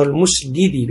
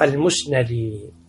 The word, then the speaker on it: المسندي: بالمسندي بفتح النون، بعض الناس ينطقونه المسندي بكسر النون وهذا خطأ، الصواب المسندي، وهو ثقة ثبت حافظ.